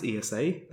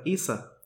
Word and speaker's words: ESA: It's not pronounced ESA, they prefer ESA.